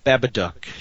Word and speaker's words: Babadook: Babadook. The Babadook. Babadook.